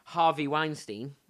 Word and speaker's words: Weinstein: I've heard that disgraced Hollywood director Harvey Weinstein, I've said his name right, haven't I? – Yep.